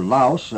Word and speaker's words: Laos: Laos, or Laos, as some of them pronounce it, the release being on June the 15th.